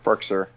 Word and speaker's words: Firkser: Firkser? I don't know how you say his name. – Firkser, yeah. – Yeah, there we go.